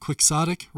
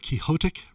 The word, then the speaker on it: Quixotic: Glenn Tilbrook, you can find that, by the way, over at Quixotic Records, or Quixotic Records, if you pronounce it like Don Quixote.